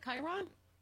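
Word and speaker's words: chyron: It's Hegseth standing in front of the cryon. – Isn't it Chiron? Chiron. I never pronounce everything right. But Chiron. – Chiron is correct. It's Chiron.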